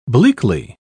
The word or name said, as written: bleakly